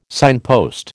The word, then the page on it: signpost: American English